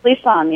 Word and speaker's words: Clisson: Is that how you pronounce it? – Clisson, yes.